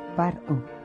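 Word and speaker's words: pr-ꜥꜣ: La palabra deriva del término egipcio PR, que los hebreos pronunciaban par-o, y que significa literalmente casa grande, esto es, palacio, el lugar donde residía el soberano.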